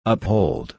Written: uphold

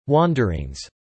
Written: wanderings

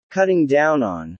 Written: cutting down on

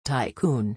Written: tycoon

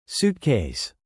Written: suitcase

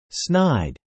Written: snide

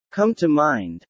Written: come to mind

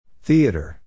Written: theater

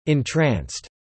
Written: entranced

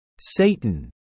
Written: Satan

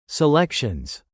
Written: selections

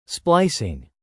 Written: splicing

